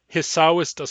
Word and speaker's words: Hisawista: In Nuchonal, Hisawista starts with an H. Hisawista, Hisawista. But in English, we spell it without an H. It's spelled Esow, Esawista. That shows us that the first English speakers who heard that name did not hear the H because the Nuchonal H is such a weak sound.